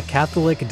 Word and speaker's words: Catholique: It's called the Catholic dialectic. Catholic spelled C-A-T-H-O-L-I-Q-U-E.